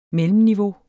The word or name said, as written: mellemniveau